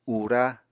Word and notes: ura: Apatani

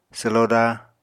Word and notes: swloda: apatani

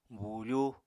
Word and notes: buufú: Apatani

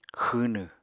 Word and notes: xwwnw: Apatani

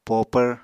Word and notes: popvr: Apatani

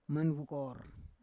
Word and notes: manvukor: Roon

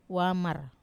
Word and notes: wamar: Wamesa

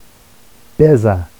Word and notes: beza: Vafsi

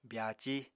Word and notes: byaací: apatani

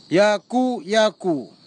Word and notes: iaku iaku: kwamera; Tanna

